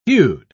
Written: hued

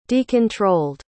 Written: decontrolled

